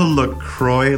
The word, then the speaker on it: La Croix: Okay, so on the La Croix La Croix website, they say that you're supposed to announce it, La Croix, which because it rhymes with enjoy.